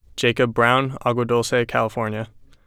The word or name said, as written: Jacob Brown